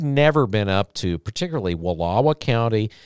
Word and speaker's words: Wallowa: Oh, you got to say it right. I'm not going to let you say it like that in here. Wallowa. – What did I say? – Wallowa. – Okay. Wallowa. – There you go. – Wallowa. – Get that twang in there.